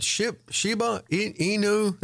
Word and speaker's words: Shiba Inu: And is it Shiba Inu? Is that how you pronounce the crypto? – Yes.